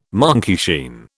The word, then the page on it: monkeyshine: British English